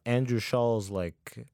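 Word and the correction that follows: Schulz: It's Schultz.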